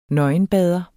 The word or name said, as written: nøgenbader